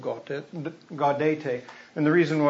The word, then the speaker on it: Gaudete: We want to call it Gaudet Sunday, but I believe it's Latin, and I'm not a Latin scholar. I never took Latin. I believe it's pronounced Gaudete. But anyway, Gaudete Sunday.